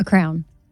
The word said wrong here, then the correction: crayon: You said crown, but it's crayon.